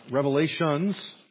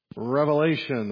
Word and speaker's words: Revelation: By the way, if you want to make sure you're saying it correctly, it's not Revelations, plural, right? It's Revelation, the revelation of Jesus Christ.